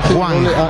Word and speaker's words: Hwang: Hwang. – Con J, ¿verdad? – Con G. H-W-A-N-G. Juan. Señor. – Y la H con W en coreano se pronuncia como J, ¿verdad? – Exacto, sí, señor.